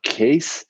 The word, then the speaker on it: Kees: I took like a three week course, case from Bizarre Glass in Amsterdam. Case and Inica de Bont are their names. – Okay. – Case is spelled K-E-E-S. It's just one of those weird Dutch pronunciation.